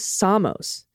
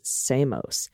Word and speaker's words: Samos: The pronunciation of the Greek island is Samos. Dana pronounced it Samos.